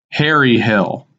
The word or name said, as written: Hairy Hill